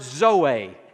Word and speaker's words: zoe: And then the third word that is the most important of all the Greek words for life is the word zoe. And you spell it Z-O-E, Zoe, and that word simply means spiritual life.